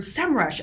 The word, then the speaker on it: Semrush: They very politely reached out to me and said, by the way, it's SEM Rush. So if you're wondering, this is how they want us to pronounce it is SEM Rush.